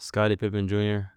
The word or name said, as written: Scotty Pippen Jr